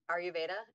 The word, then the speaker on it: Ayurveda: So it's really interesting that you mentioned Ayurveda.